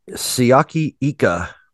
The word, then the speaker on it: Siaki Ika: Siaki Ika.